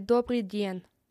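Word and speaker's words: Dobry den (Добрый день): Dobry den.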